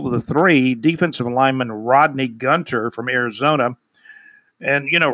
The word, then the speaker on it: Gunter: Let me make sure I pronounce his name right. He is brand new. Defensive lineman Rodney Gunter. That's how you pronounce his name, Gunter, G-U-N-T-E-R.